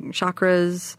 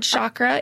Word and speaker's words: chakra: You'll hear it said chakra. In my training, we had a Sanskrit expert come in and he said it's actually called chakra because the CH in Sanskrit makes a ch sound, which, you know, it's neither here nor there.